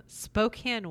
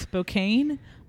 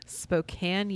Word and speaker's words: Spokane: I've heard of it, but in a little joke of to myself, I actually thought it was pronounced Spokane until I watched an interview where he was at the public library of Spokane, and I thought, oh, I've been saying it wrong.